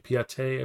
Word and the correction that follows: Pietà: I believe it is. Pose. – The Pietà.